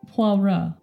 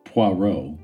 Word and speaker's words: Poirot: So, Poirot. Mm-hmm. Poirot. – Poirot. – Row. – Yes. – I think I said it right before. – You said Poirot.